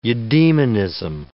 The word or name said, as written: eudaemonism